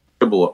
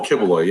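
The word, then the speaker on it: Quiboloy: Quiloboy. – Oh, Quiloboy.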